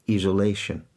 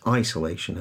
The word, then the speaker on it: isolation: Yeah, it's the long I that in a lot of languages just doesn't exist. So people are saying virus and isolation, whereas it's isolation and virus and quarantine, which doesn't have the long I. It has an E sound, quarantine.